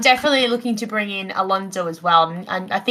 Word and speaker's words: Alonso: Yeah, Alonso, yeah.